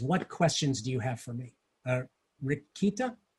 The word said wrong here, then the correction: Ritika: Yeah, I pronounced my name Ritika. – Ritika.